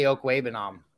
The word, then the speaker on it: Okwuegbunam: It's really crazy that people just can't say Okwuegbunam. Like, it's not that hard.